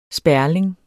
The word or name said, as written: sperling